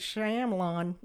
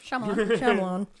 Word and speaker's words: Shyamalan: That's that podcast where they started with M. Night Shyamalan. Mm-hmm. I didn't say that right. – You were close. – Shyamalan. They